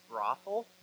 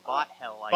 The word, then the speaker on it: Bothell: The two that I get all the time is Brothell. – Brothell. – Yeah, and, exactly. That's what I was thinking. – And it's spelled B-O-T-H-E-L-L, so Bothell. – Bothell, yeah. All right, Bothell.